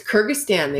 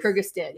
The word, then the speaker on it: Kyrgyzstan: It was Kyrgyzstan they went to. – Oh, Kyrgyzstan. I couldn't pronounce it. Yeah. Thank you. – I'm pretty sure that's what I... – No, it is. – Kyrgyzstan.